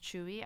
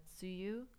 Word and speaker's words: Tzuyu: Okay, so first correction is T-Z-U-Y-U is pronounced Chewy. I know I was pronouncing it as Tsuyu.